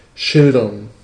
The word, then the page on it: peindre: France; /pɛ̃dʁ/